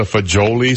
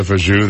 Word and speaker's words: fagioli: I know it's pasta for, it looks like pasta fagioli soup, but it's really pasta fajou. That I know, and I've always pronounced it that way.